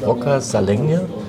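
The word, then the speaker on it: Roccascalegna: Wie spricht man das aus? Rocca Salegna? – Also, noch ein paar Wochen oder ein paar Tage, dann gehst du auch langsam mal die Italiener durch. Ja, genau so spricht man es aus.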